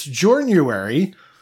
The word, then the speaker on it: Journuary: pronounced the month incorrectly. It's Journuary, which is the journey. Journuary is the clever pun there.